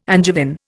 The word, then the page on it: Angevin: Indian